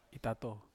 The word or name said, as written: tato